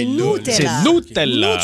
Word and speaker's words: Nutella: Ben c'est le U en italien se prononce OU comme OU. – Donc c'est Nutella.